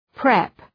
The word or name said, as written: prep